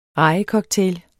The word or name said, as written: rejecocktail